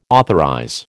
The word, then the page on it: authorize: American English